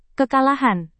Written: kekalahan

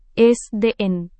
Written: sdn

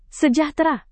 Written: sejahtera

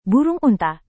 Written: burung unta

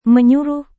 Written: menyuruh

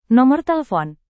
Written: nomor telepon

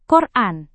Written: koran

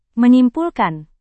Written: menyimpulkan

